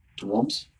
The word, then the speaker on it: Toms: But if you really want to be correct, then even my first name is a bit different. – Yeah. – It's Toms. – Toms? – Yeah.